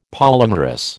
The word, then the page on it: polymerous: American English